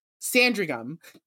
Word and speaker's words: Sandringham: During the Sandringham, am I saying that right? I don't know why I struggled with that one. Sandringham, thank you.